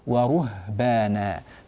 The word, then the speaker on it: ورهبانا: ورهبانا حرف الهاء ساكن والراء مضمومة، ضمت الشفتين في الراء ويعني انتبهنا لذلك، لكنك أبقيت شفتيك مضمومتين وأنت تلفظ بالهاء الساكنة، لماذا؟ روح لرو، وعد بشفتيك إلى الهاء ورهبانا، فلا تبقي شفتيك مضمومة، وهذه يعني قاعدة، كل حرف ساكن وقبله مضموم نتنبه له، نضم للحرف المضموم ثم نعود بشفتينا للحرف الساكن.